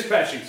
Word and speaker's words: Pesci: It is Pesci, so it's like the act.